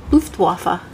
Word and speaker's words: Luftwaffe: I think you're saying it wrong. – Luftwaffe.